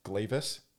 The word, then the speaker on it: Glavis: And uh, and this uh, planetary ring has been given a name of uh, I think it's it's pronounced Glavis. It's G L A V I S, uh, like this Gla- Glavis or Glavis, yeah.